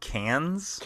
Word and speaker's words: Cannes: It's pronounced Cannes? – Cannes. – Cannes? – Cannes? – Cannes. – Like Kansas? – In a rare... – It's like Kansas. – Nope. No. – Okay.